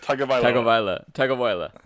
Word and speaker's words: Tagovailoa: Well, with the fifth pick in the 2020 NFL Draft, the Miami Dolphins select Tua Tagovailoa. I don't know how to say his last name. – You should learn how to pronounce his name if he's going to be your franchise quarterback, sir. – Tagovailoa.